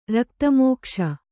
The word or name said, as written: ರಕ್ತಮೋಕ್ಷ